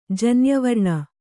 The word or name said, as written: ಜನ್ಯವರ್ಣ